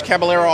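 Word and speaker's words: Cabaleiro: If you just search Caballero Art, and I'll spell that. It's C A B as in boy, A L E I R O, and it's pronounced just the way it's spelled.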